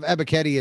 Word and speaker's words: Ebiketie: According to my pronunciation key, Ebiketie. – Yeah, there you go. Ebiketie. Yeah, you're good.